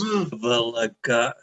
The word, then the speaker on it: Bvlgari: It's pronounced Bvlgari. It's BV. – V. BV, those idiots.